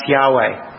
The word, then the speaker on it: Yahweh: In the Hebrew, in the Old Testament, it's four Hebrew letters, sometimes pronounced Yahweh.